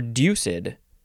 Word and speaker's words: deuced: The next word is deuced or deuced. It is two syllables. I would have thought deuced, but no, it is deuced.